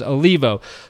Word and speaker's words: Olivo: I was like, there's definitely not a second I in this name. Yeah. And it is Olivo.